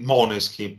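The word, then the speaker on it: Måneskin: E quindi e eh, devo dire ora, per esempio, questo discorso di parlare dei maneskin, moneskin poi in realtà sarebbe la, la pronuncia più, più mh, mm, corretta,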